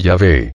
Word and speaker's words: Yahvé: Las pronunciaciones Jehová y Yahvé. Artículo principal. Jehová. – Normalmente los hebraístas sugieren que la pronunciación más exacta del tetragrama sería Yahvé.